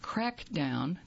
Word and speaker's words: Craicdown: They're going to have Crackdown, that's spelled C-R-A-I-C-D-O-W-N. It's free, and it will be Friday, July the 15th from 7 to 9 p.m.